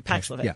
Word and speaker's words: Paxlovid: But my doctor said, you know what, just to be on the safe side, you should take the Paxlovid. I'm pronouncing it right, right? – Paxlovid, yeah.